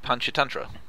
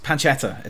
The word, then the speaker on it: Panchatantra: Oh, Panchatantra. – I'm just Panchetta.